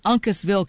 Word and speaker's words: Uncasville: And I always wondered if I pronounced the name of that town correctly, because I've seen it in several towns, or rather several tour announcements with different bands. They like to play at Uncasville, Connecticut. I didn't know if I was pronouncing it correctly. I looked it up, and I am.